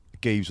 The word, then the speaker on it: Jeeves: Geeves with a hard G, actually.